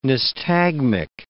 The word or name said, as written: nystagmic